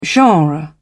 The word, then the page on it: genre: British English